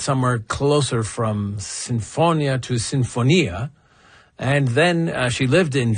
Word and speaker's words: sinfonia: So the author of this letter said that as an Italian speaker, he found it grating when he heard me say sinfonia, because in Italian that word, which is an Italian word, would be sinfonia. Now, in this case, this composer, Mariana Martinez, lived in Vienna. So there it would be pronounced sinfonia, or something closer to that.